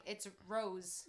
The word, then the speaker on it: rose: Another person, Tayshia, the way she says Rose, she does not pronounce the Z. – But we might not say it right. – No, I'm just saying. – We YouTubed it. We say it right. It's Rose. – Rose. But I'm telling you, I'm telling you, one of the criteria for being on The Bachelor, Bachelorette, they make them say Rose. With a soft S instead of a Rose with a Z.